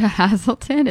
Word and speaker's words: Haselton: It's Alicia Hasselton. It's spelled A-L-E-C-I-A. And Hasselton is H-A-S-E-L-T-O-N.